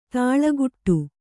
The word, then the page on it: ದಳೆಕಟ್ಟು: Kannada